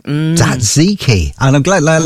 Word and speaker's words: tzatziki: There's a lot of T's and Z's in that, and I'm never quite sure. Just tzatziki. Cough through it. It'll be all right. – Tzatziki.